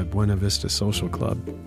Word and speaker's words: Buena Vista Social Club: Coming up, it's Fresh Air, and also looking forward to the Buena Vista Social Club.